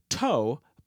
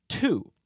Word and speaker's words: two: But today, we don't pronounce the word as toe, but as two. So, in addition to the diminishing pronunciation of that W, the vowel sound at the end of the word changed from O to ooh.